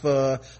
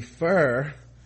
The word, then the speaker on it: pho: One, pho is pronounced pho, or even more accurately, fur, F-U-H-R.